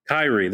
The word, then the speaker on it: Kyrie: Kyrie, there it is.